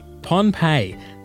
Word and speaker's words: Pohnpei: Pompeii, that's spelled P-O-H-N-P-E-I.